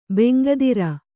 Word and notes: ಬೆಂಗದಿರು: bengadiru

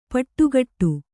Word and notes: ಪುಟಗೊಡು: puṭagoḍu

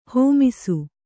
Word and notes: ಹೇಮಿಸು: hēmisu

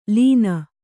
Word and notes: ಲೇನು: lēnu

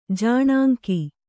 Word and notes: ಜಾಣಾಂಕಿ: kannada

